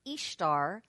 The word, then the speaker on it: Ishtar: The Phoenicians called her Astarte, the Assyrians and Babylonians called her Ishtar, pronounced just as the word Easter, and in Europe she was known as Ostrara.